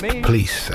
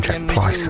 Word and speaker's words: Pleis: Jack Kenneth Pleese, it's spelled P-L-E-I-S, and he is quoted as saying it's pronounced police, as in fleece or grease. It's because everyone used to call him Jack Pleese, and then that's not how you say it.